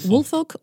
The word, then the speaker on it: Woolfolk: I know, the L turns into an R sometimes. I say I just forget about the L, and I go, Woolfolk. – All right, Woolfolk was charged.